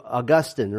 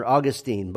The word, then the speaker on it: Augustine: It's a guy by the name of Augustine or Augustine. Both pronunciations are correct.